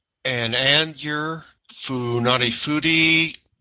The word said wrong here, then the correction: Funafuti: no, Funafuti, Yaren, and Tuarwa.